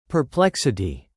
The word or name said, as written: perplexity